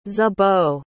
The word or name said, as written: zibo